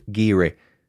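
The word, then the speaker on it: Garioch: Geary comes from the Gaelic word meaning place of roughness, but it's not spelt how you would think. I had to research this one. It's G-A-R-I-O-C-H, but I'm assured it's pronounced Geary.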